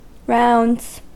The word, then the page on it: rounds: American English